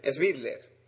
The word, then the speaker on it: Svidler: Moriosevich, Grishuk, Sblit, a ver si lo digo bien, Svidler eh, Tomaszewski, Yakovenko Andrejkin, Riazansev, Nepomniamchi y Malajo.